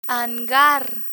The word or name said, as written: hangar